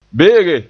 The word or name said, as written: be'ege